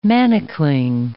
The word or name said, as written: manacling